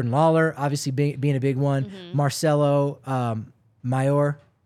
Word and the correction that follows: Mayer: Why am I saying Mayor? Marcelo Mayer. I was criticized for my pronunciation for him recently, so now I'm second-guessing myself. Like the hot dog, Oscar. So maybe it's Mayer. Is it Mayer or Meyer? – Oscar Meyer, but it's...